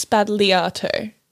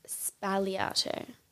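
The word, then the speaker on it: Sbagliato: Apparently, it's Spagliato, no G. – Spagliato. – The G is silent. I saw that on Sophia and Sydney's TikTok. Shout out. – So she said it wrong? – Yeah, apparently you don't pronounce the G.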